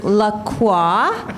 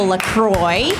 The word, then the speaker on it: La Croix: I brought my favorite beverage, grapefruit flavored La Croix, sometimes pronounced by uncultured people La Croix.